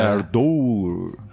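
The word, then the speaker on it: Ardour: Então aqui no lado de cá eu gravo utilizando o software Ardor, tá? – Ardor, né? Tem que ver, tem um U, né? Então é Ardor.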